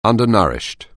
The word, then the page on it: undernourished: anglais